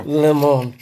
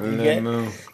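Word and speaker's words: Limón: Limon. – You gotta say it like that. – Limon. – Can't say it any different. You're gonna insult the drink.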